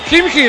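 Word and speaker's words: Khimki: Valencia Basket 24, Kimchi de Moscú, bien pronunciado, 20.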